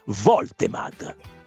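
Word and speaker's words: Woltemade: È tedesco. Si pronuncia come è scritto. La W iniziale si legge come una V. Quindi Voltemad. Voltemad. Si pronuncia come è scritto Voltemad.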